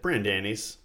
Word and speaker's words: Brandani's: Brandani's? How do you say it? – Brandani's. – Brandani's, Pudgie's, and Acme.